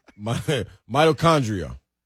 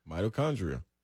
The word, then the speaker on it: mitochondria: Mitochondria. Did I say that right? – Yeah. – Mitochondria.